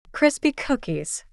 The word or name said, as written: crispy cookies